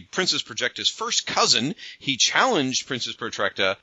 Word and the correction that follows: Projectra: I can't say her name for some reason. – We call that Projectra.